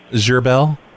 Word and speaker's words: Gherbil: Gerbel. – How do I spell that? – G-H-E-R-B-I-L.